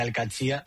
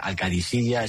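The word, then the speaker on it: Al-Katsiya (القادسية): Bueno, se suele decir Al-Katsiya, pero también hay gente que lo dice Al-Kadisiya. Es que es difícil, ¿eh? Ah. No es fácil, pero Al-Katsiya es lo más fácil.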